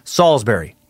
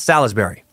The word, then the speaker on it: Salisbury: Salisbury is pronounced Salisbury, not Salisbury.